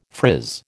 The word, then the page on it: frizz: American English